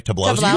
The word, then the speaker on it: Tobolowsky: Joe, am I saying it right? Tobolowsky? – You are. You betcha.